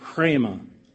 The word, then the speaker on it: rhema: "So then faith cometh by hearing, and hearing by the R-A-M-A, pronounced rhema, of God."